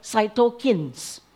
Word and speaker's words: cytokines: Our body come up with a chemical called cytokines. And these cytokines, I didn't check the pronunciation. Is there a medical person here that can? Is it cytokines or cytokines? Cytokines.